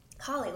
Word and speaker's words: Halle: I don't mind that Halle Bailey is mm-hmm. Halle Bailey. Mm-hmm. Halle Bailey. How do you fucking, I can't. – Holly, like. – Holly. Oh, Holly. Like H-O-L-L-Y.